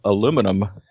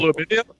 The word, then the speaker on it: aluminium: What about the proper pronunciation of aluminum? – Ooh, that'll get people going. – Aluminium? – It's aluminium, isn't it? – People are very passionate about that, too.